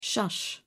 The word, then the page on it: shush: British English